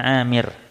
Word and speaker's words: Amir: Lalu wali Abi Dawud bisanadin sahih an Urwah bin Amir. Amir bacanya Amir.